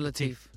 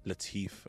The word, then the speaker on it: Latif: Shahzad Latif. – Latif, okay.